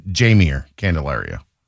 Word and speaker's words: Jaime Candelario: I've heard it pronounced both ways. I tend to think Jaime, right? Like that would be the proper Latin pronunciation. But the official pronunciation from MLB said Jaime Candelario.